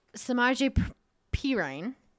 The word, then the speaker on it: Samaje Perine: Now, some key losses for the Bengals was Samaje Perine.